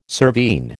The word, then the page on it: cervine: American English